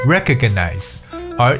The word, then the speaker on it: recognize: recognize，r e c o n。 excuse me, it's R E C O G N I Z E recognize 这个词在发音的时候可能有些稍微的古怪，要稍微快一点。